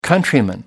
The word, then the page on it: countryman: American English